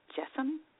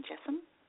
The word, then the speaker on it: Jassem: And I just want to check out, have a new listener, Lynn, and I want to pronounce her name right, and I believe it's Jessam, Lynn Jessam, and so Lynn Jessam, J-A-S-S-E-M, she's got a one-woman show,